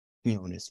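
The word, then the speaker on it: Quiñones: Is that how you would say it? Quinones? – Quinones. Yes. That's, Quinones. – That's how it's said.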